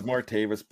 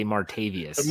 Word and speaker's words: Martavis: It is Martavis Bryant, and the rest of them are really easy. So traded the Raiders, signed with the Argonauts, stuff like that. – But you didn't pronounce it correctly for those in Pittsburgh. They say Martavius.